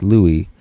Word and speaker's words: Louis: So Louis, pronounced Louis, and then capital XIV, which is Roman numeral 14.